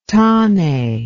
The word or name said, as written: tane